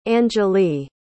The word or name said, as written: angelea